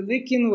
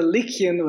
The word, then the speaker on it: Lycian: The Lycian Way, first of all, it's the Lycian Way, not the Lycian.